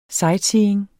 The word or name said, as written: sightseeing